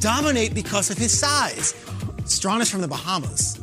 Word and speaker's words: Strachan: It is Colts wide receiver Michael Strawn. Uh-huh. Now, his name is spelt Strachan, like the Hall of Famer Strahan with a C, but it's pronounced Strawn. His story is incredible.